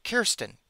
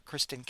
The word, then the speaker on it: Kirsten: Quote, DAFS Commissioner Kirsten Figueroa, F-I-G-U-E-R-O-A, we're just going to say Kirsten, not Kristen, Kirsten, I can't pronounce her last name, my apologies.